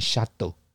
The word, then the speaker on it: château: 在波尔多有城堡酒庄叫 Chateau，嗯，你可以在酒标上面看到 C H A T E A U，Chateau，然后那个 A 上面有一个盖子，有一个尖尖的那个盖子，那是法文的拼法，那其实不会影响发音的，就是念 Château。